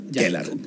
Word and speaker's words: Ckelar: Se escribe C-K-E-L-A-R. Kelar. – Kelar, pero con una C antes de la K. – Antes de la K, sí.